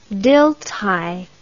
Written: Dilthey

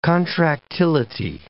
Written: contractility

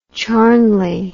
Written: charnley

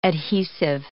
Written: adhesive

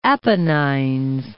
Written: Apennines

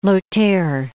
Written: Lothair I

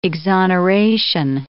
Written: exoneration

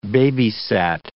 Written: babysat

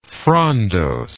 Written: frondose

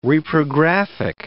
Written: reprographic

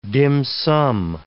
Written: dim sum

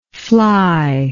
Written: flyness

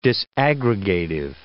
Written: disaggregative

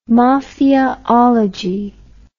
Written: mafiaology

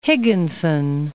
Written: Higginson

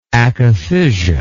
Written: akathisia

